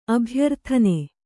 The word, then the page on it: ಅಭ್ಯರ್ಥನ: Kannada